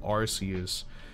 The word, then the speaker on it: Arceus: He's half Japanese, and he like cringes when I say Arceus. He's like, it's pronounced Arceus.